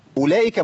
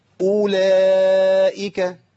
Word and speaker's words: أولئك: طيب وأولئك، عندنا واو بتاعت أولئك كتير من الناس بيظهرها بينطقها، ده طب ما هي مرسومة قدامنا يا شيخ، هي في واو مرسومة بين قوي بعد الهمزة. نعم. خدوا بالكم، اي واو عليها صفر مستدير او اي حرف عليه صفر مستدير كده صغير زي ده بالظبط، الحرف ده لا ينطق.